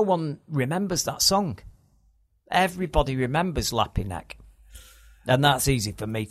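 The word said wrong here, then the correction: Lapti Nek: Is that how you pronounce it? I don't know. – No, I think it's Lapty Neck. – Lapty Neck.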